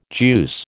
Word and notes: juice: American English

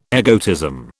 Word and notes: egotism: British English

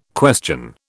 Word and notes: question: British English

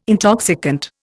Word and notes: intoxicant: Indian

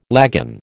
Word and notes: lagan: American English

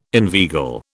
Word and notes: inveigle: British English

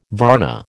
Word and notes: varna: American English

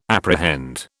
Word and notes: apprehend: British English

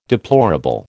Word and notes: deplorable: American English

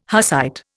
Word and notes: Hussite: Indian

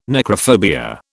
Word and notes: necrophobia: British English

